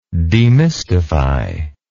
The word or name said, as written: demystifier